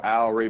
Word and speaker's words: Al Wray: So Al Wray Basketball, that's spelled ALWRAY Basketball.